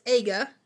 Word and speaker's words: aga: I believe I pronounced that incorrectly earlier or mispronounced it. So it's aga, noun.